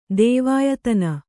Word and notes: ದೇವಾಯತನ: kannada

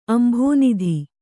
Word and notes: ಅಂಭೋನಿಧಿ: Kannada

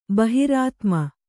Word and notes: ಬಹಿರಾತ್ಮ: kannada

